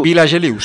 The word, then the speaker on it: Vilageliu: Vilageliu, sí.